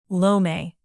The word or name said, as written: lome